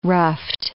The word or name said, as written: ruffed